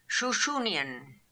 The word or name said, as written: Shooshoonian